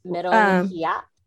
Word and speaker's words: meronghiya: What is your Filipino moronghiya pleasure? – Meronghiya. – Did I say that right? – Instead of walanghiya, it's meronghiya.